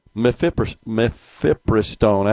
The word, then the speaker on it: mifepristone: But this would have restricted access to the abortion pill mifepristone. I have a hard time pronouncing that. Did I say it right, Rhino? Mifepristone. – Yeah.